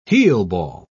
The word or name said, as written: heelball